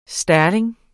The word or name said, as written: sterling